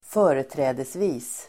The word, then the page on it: företrädesvis: ²f'ö:reträ:desvi:s